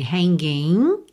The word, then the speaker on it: hanging: O G final de hanging não é pronunciado, certo? Você termina o som mesmo no N. Hanging.